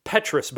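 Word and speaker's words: petrous: It's because one of the big jumps was we found a whole bunch of DNA hidden in the, and I mispronounced this last week, I said Patronus, which is a Harry Potter spell, Petrus bone, in the Petrus bone, way more DNA than we thought, and so now we're able to find ancient DNA where we thought there was none.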